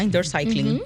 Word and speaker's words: indoor cycling: Estamos aprendiendo muchísimo en el día de hoy sobre indoor cycle. ¿Así se pronuncia? Ajá, indoor cycling. – Como debe ser.